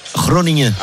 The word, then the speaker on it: Groningen: pour le joueur formé à Groningen, si ma prononciation est bonne. – Mais voilà, là, tu pourras pas le prononcer en néerlandais. – Groningen, on t'écoute. – Groningen.